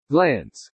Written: glance

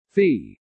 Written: fee

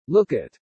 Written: look at

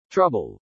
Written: trouble

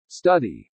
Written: study

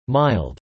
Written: mild

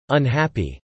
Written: unhappy

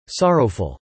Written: sorrowful

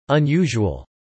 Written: unusual

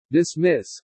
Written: dismiss

